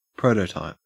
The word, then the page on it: prototype: Australian English